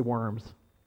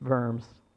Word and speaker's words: Worms: I want to say Worms, but it's Worms.